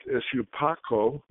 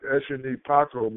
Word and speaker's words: Eshunipako: The back of the neck, Eshupako, which is frequently mispronounced in the diaspora, but Eshunipako means Eshu cuts off my head.